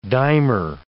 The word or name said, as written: dimer